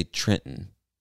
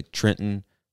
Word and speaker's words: Trenton: I would say Trenton. So that second T becomes silent, basically. Or like Trenton. Yeah, becomes silent. We don't pronounce it like a normal T. So we would say Trenton, New Jersey.